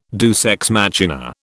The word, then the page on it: deus ex machina: British English